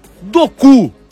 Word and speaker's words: Doku: Duas modificações do Rudi Garcia, as entradas de De Bruyne no comando ali na articulação das jogadas, e o Jeremy, Doku, é isso mesmo, isso, no comando de ataque, vai, não...